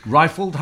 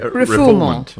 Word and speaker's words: refoulement: of being, refouled? Rifled? How do you pronounce it? – Refoulement. – We are not expert linguists. We make pretentious claims for our legal ability, but not our language. – So you usually say refoulement.